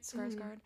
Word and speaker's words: Skarsgård: Skarsgård? – Mm-hmm.